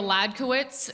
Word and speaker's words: Hledkowicz: It's spelled H-L-E-D-K-O-W-I-C-Z. Looks like an unsolved Wheel of Fortune puzzle. So that's good. A host the other day brought me to stage and they're like, please welcome Julia Halakawacki.